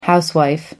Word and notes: housewife: American English

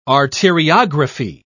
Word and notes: arteriography: British English

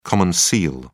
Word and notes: common seal: British English

